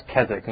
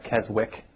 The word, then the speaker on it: Keswick: Keswick, and it's pronounced Keswick, not Keswick, W silent.